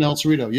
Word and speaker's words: El Cerrito: Kensington El Cerrito.